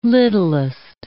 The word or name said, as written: littlest